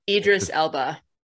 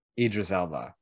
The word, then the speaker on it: Idris Elba: Idris Elba. Idris Elba.